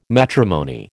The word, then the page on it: matrimony: American English